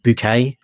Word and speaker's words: Bucket: But the matriarch of the family is this, she's aspiring to higher things, so she pronounces her name Bouquet.